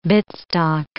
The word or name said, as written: bitstock